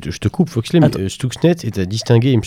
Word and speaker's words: Stuxnet: Stuxnet, voilà, tu, le, prononce beaucoup mieux.